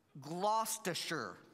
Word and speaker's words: Gloucestershire: It's Gloucestershire.